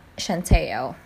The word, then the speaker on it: Centineo: His name is Noah Centineo. That's right. His last name is not pronounced as Centineo. You know, it's softer, more relaxed, like a slow wave across a smooth, pebbled coastline.